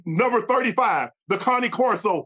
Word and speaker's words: Cane Corso: Let's talk about the Kangol. – What? – Where did it originate from? The Kangol. – The Cane Corso? – It's pronounced Cane Corso, but okay, go ahead, take off.